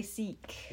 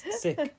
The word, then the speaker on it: Sikh: In the UK, we say Sikh. – Yeah. – Yeah. So it is a bit confusing. – We'd assume that Sikh is sick is maybe more authentically how you pronounce it. I'm not sure. Because I feel why would it be? – But like Sikh. Like, the long E sounds something more like Americans would put.